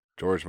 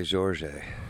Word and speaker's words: Jorge: That's George Masvidal. It's pronounced spelled the same way. Just pronounced. – I was gonna say Jorge.